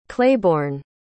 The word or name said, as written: claiborne